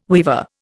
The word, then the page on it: weever: Indian